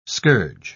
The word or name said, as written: scourge